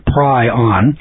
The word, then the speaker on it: prion: It's pronounced P-R-Y-O-N, prion. A protein-based molecule with no RNA or DNA that is similar and is smaller than a virus.